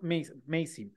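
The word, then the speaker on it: Massee: que es Michael Missy, Missy, Macy, no sé cómo se pronuncia. Este, pero eh, bueno, si quieres tú, dale, dale café. – Mira, déjame. Eh, sí, es Michael Macy. Macy.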